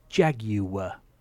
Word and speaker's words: Jaguar: It's three syllables. Jaguar.